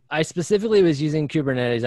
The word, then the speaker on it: Kubernetes: And I'm going to expose my ignorance here, but one of the ones you put on LinkedIn was Kuber Kuber something, and Kubernetes. yeah, Kubernetes.